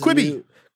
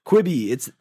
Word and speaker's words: Quibi: That's Quibi. – Quibi, it's the new shit.